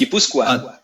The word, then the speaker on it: Gipuzkoa: Gipuzkoa.